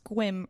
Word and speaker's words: Sequim: So it says Sequim, Washington, but he says it's pronounced Squim, rhymes with whim.